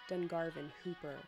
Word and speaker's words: Dungarvon Whooper: The tale of the Dungarvon Whooper, locally pronounced Dungarvon Hooper, the Dungarvon Hooper,